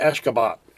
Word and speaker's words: Ashgabat: Just that I keep adding an R in. It's just probably Ashgabat is probably how you say it.